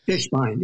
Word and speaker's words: Fishbein: And then also the story of Morris Fishbein or Fishbein. I don't know how you would pronounce that. – Fishbein, yeah.